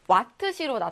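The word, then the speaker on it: Wh: 그러면 단위는 와트시로 나타내 주면 돼요. 와트에다가 시간을 곱한 거고 우리 아워를 뜻하겠죠? 읽을 때는 와트시라고 읽어 주시면 됩니다.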